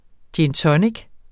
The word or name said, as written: gin